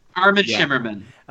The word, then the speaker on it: Armin Shimerman: He does, in the book Slayers and Vampires, the oral history, I don't even know how to say his name, Armin Shimerman? – Armin Shimerman. – Okay.